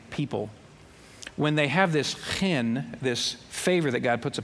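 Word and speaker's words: chen (חן): I know that it's not that I have to clear my throat. Chen is how they say that. I'm no Jewish linguist, but chen is how you say that, as I understand it.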